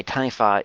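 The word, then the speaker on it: taniwha: Historically, in legends that you will hear of the Tanifa, you have stories about So first of all, I should say Tanifa is spelled, if you're looking for it online for more information, it's T-A-N-I-W-H-A. The W-H in transliterated Māori tongue is pronounced as an F, so Tanifa.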